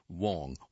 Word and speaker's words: Wang: Wang is spelled W-A-N-G, may be pronounced Wong, Wong or Wang.